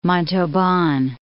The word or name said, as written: Montauban